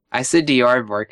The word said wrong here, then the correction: Dvorak: It's probably not how it's pronounced, though. – Yeah, like I think it's pronounced Dvorak.